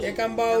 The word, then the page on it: kambau: Ansus